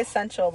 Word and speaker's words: Essentia: It's Essentia. There's no L.